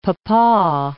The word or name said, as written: papaw